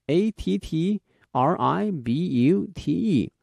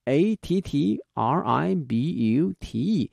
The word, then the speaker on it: attribute: 好，那么今天的第二个单词是 attribute， a t t r i b u t e， a t t r i b u t e。那么这个词啊，它有两个发音。作为动词的话呢，念成 attribute；如果是名词哈，它的发音变成了 attribute 啊，attribute 意思呢也不一样。